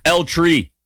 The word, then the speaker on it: El Tri: where I know El Tri. I said that as white as possible. El Tri? It's not a place where you roll your R. El Tri.